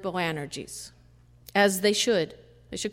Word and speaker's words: Boanerges: Maybe I'm saying it right, maybe not. Maybe Rick can tell me. Boanerges. Close enough? Yeah.